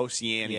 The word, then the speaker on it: Oceania: Oceania.